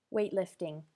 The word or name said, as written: weightlifting